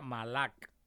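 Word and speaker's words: malak (מלאך): Y dijimos que se pronuncia malak en hebreo, se pronuncia ángel.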